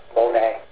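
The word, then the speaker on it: Monet (μονή): It's the Greek word M-O-N-E. – Oh. – Monet. I wrote it phonetically so I could pronounce it correctly.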